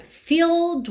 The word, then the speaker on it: filled: Na primeira, filled with regret. Filled, esse E agora já não é pronunciado. Então do L você vai direto pro D.